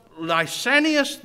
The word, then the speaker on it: Lysanias: In Luke's announcement of Jesus' public ministry in Luke 3 and 1, he mentions one Lysanias, the tetrarch of Abilene. Now I'm pronouncing it like the Greek does.